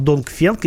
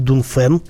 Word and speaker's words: Dongfeng: На самом деле э, фирма Dongfeng, или правильно, наверное, произносить Dunfen.